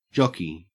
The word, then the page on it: jockey: Australian English